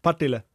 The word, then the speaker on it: Partille: Ja, Partille.